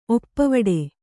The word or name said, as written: ಒಪ್ಪುವಡೆ